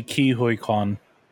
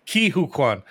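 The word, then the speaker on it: Ke Huy Quan: Kwei Hung Kwan, is that it? – It's actually Ki Hui Kwan. – Damn it, so close. Ki Hui Kwan.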